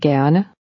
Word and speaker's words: gerne: Det vil jeg gerne.